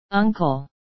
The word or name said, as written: uncle